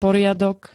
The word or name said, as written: poriadok